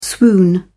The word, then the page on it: swoon: British English